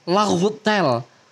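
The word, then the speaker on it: Laugh Tale: Dan ternyata pelafalannya Raftel tuh pelafalannya Jepang, bukan Raftel tapi Lautel. Lautel.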